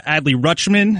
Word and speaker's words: Adley Rutschman: My guy, Adley Rutschman, am I saying that right? Yep.